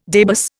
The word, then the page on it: debus: Indian